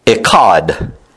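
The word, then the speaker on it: echad (אחד): So this word first is the Hebrew word echad.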